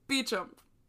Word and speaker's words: Beauchamp: If you recall, Israel Beauchamp. – Beauchamp. You're the one who Beauchamp. – Told me how to pronounce it. – I know. It's like Beauchamp. Beauchamp. Was